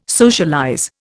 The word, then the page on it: socialize: Indian